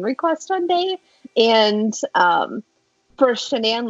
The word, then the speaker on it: Shanann: So, Chris met his wife, and I know I'm probably going to pronounce her name wrong, like, a bunch of times, because it's Shanann. But I always want to say Shannon. But it's Shanann.